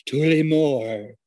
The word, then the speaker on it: Tullamore: And I'm laying down and drinking this Irish Tullamore. Oh my God, I actually pronounced it right this time.